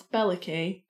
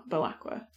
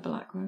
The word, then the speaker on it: Belacqua: So, and Count Belacqua, which I used to pronounce Beliki, and then I saw the TV show and they pronounced it Bal- Belacqua. – Oh. Yeah, I've always said Belacqua.